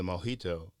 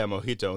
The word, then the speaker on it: mojito: And I was like, oh, yeah, mojito. And they go, oh, and then start speaking Spanish. I'm like, just because I know J's are pronounced with an H.